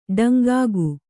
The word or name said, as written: ಡೊಂಕುಗ